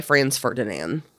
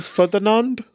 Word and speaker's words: Franz Ferdinand: Franz Ferdinand? – Of me. – Is that how they say it? Franz Ferdinand? – I believe so.